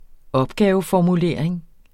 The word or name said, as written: opgaveformulering